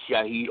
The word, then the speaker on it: Shaheed: First of all, my name is Shahid Omar, and that's spelled S-H-A-H-E-E-D. My last name is Omar, O-M-A-R.